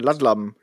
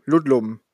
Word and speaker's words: Ludlum: Ludlum, Ludlum, der die Born-Dinger gemacht hat.